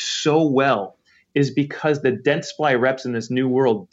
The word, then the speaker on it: Dentsply: It's Dentsply. – So Dentsply. – It's two syllables,